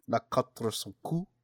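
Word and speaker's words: Les Quatre Cents Coups: How would you pronounce it? – Is it Le Quatre Cent Coups? – Le Quatre Cent Coups.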